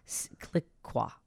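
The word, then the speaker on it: Clicquot: Cliqua. Otherwise, if I say it the way it looks like it's pronounced, like the way it's spelled, I might get into some trouble with the FCC.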